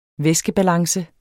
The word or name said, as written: væskebalance